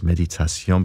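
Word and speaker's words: Méditation: Okay, here's this piece involving meditation, or I should pronounce it in French, of course, Meditation, because this is from French composer Jules Massenet, and it's one of his most famous pieces, if not the most famous.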